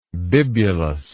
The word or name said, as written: bibulosity